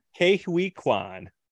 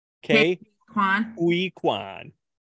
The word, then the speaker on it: Ke Huy Quan: So I'm hoping, though, that Ke Huy Quan. – Ke Huy Quan.